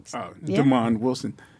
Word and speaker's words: DeMond: And Baby, I'm Back with, it was Damon Wilson's DeMond. – First. – DeMond Wilson. Uh-huh. – Yeah, I never, that's how he pronounced his name? – Yeah. Yeah. – DeMond.